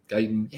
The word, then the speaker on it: Guyton: I think the interesting play that if you're looking for a inexpensive play in that game, some people will look to Guten or Guyton or however you say his name. – Guyton.